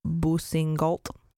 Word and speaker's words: Boussingault: whose last name is Boussingault. I'm trying my best here with the French pronunciation. – That's fine. Sounded good in my ears.